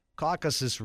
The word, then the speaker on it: Caucasus: I always mispronounce this, Caucasus region.